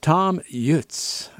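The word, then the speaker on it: Thomm Jutz: I know you wouldn't pronounce it like I've been pronouncing it if you were over there, but Tom Jutz is, I believe, the proper way to say it.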